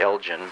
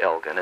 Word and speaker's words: Elgin: Those of us from the West would probably pronounce it Elgin, but they call it Elgin.